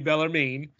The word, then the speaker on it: Bellarmine: I want to say Bellarmine, but I put pronounce it to Google and it told me Bellarmine down in Louisville, Kentucky.